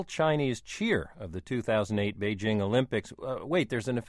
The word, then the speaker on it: Beijing: So I think a lot of people simply adopt a French-sounding je sound rather than the very simple English jing.